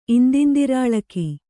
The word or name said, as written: ಇಂದಿಂದಿರಾಳಕಿ